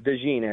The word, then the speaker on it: DeJean: Cooper DeJean, is that how you say it? DeJean or DeJean? – DeJean, actually. – DeJean? Yep. Kind of an interesting player.